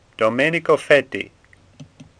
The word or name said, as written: Fetti